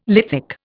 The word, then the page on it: lithic: Indian